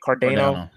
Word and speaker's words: Cardano: Is that how you say it? Cardano. ADA. Yeah. Yep.